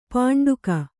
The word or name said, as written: ಪಿಂಡಕ